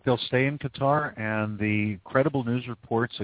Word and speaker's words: Qatar: Qatar is pronounced Qatar in that particular dialect.